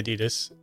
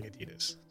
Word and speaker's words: Adidas: When I say Adidas, is it not Adidas? Is it Adidas, or are you just being silly? – No, it's Adidas. – Is it Adidas. Really? Because I've never heard anyone say it that way. – I guess it's a German pronunciation.